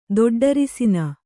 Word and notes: ದೊಡ್ಡರಿಸಿನ: doḍḍarisina

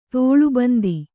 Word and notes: ತಾಳೇಬಂದು: tāḷē bandu

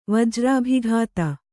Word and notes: ವಜ್ರಾಭಿಘಾತ: Kannada

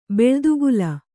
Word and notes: ಬೆಳ್ದುಗುಲ: Kannada

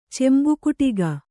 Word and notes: ಚಂಬುಕುಟ್ಟಗ: cambukuṭṭaga

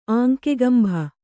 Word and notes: ಆಂಕೆಗಂಭ: Kannada

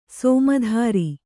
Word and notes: ಸಮುದ್ರ: Kannada

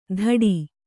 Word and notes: ಧಡಿ: Kannada

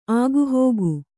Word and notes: ಆಗುಹೋಗು: āgu hōgu

